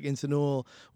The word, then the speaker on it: Insanul: I am different, and uh, you know I think having a name like Insanul, where again even like from the first time people meet me, it's like a difficult name to pronounce.